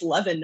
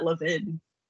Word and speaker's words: Levin: It's Levin, not Levin.